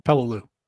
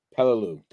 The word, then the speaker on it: Peleliu: You know, Taro was one of them, Iwo Jima was one of them, where they I'm going to mispronounce it, but is it Pilo? – Peleliu. – Peleliu. Thank you.